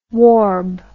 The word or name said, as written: warb